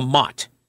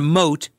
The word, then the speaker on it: Motte: It's called the Mott, okay? Not a moat, but a Mott. A moat would be around the Mott, okay? But the Mott, M-O-T-T-E is how you spell that, the Mott.